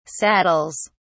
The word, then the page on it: saddles: British English